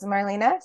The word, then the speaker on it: Marlene: It's Marlena, spelled like Marlene to a lot of people, Ditzig, or on LinkedIn, The-ish, which is the company that I run now.